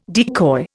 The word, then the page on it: decoy: Indian